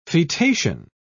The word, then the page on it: fetation: British English